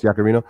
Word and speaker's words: Yaccarino: So if you're looking at why, and I don't want to mispronounce her name, but Linda Yaccarino, how's that? Is that close, Yaccarino? – Got it.